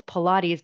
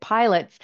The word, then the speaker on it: Pilates: Okay, I know now that it's Pilates, but I was actually pronouncing it pilots until I saw in the article, how they have the phonetic pronunciation.